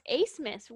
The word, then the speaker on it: Abmus: I mean, it's unreal how much I think it's Max Abmus and Kevin. – It's actually pronounced Asmus, which is Asmus? Very okay. Strange because there's a B in there.